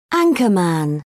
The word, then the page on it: anchorman: British English